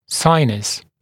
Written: sinus

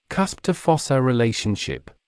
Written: cusp to fossa relationship

